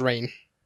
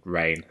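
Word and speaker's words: Rahne: So yeah, I have reason to believe that it's pronounced Rain. – On the Jane Miles podcast, they say Rain, and they know their X-Men.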